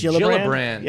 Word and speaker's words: Gillibrand: I think that's typically Gillibrand. Yeah. – Gillibrand, but it's one L then two L's.